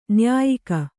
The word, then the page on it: ನ್ಯಾಯಿಗ: nyāyiga